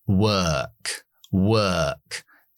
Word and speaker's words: work: O más R después de W siempre es la schwa larga. Uh, uh, work, work. Si quieres el acento estadounidense, pronunciamos la R.